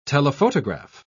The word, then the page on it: telephotograph: British English